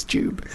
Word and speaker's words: Jube: So there's no J. So they're just like Goob. Oi, Goob. Propagates. It's like, oh, Goob, I love your videos. I'm like, yeah, it's Jube.